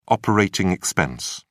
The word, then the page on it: operating expense: British English